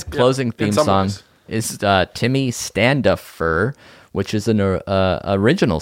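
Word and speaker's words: Tiqmmy: And you can find him on Instagram, Twitter, Facebook under Timmy as well.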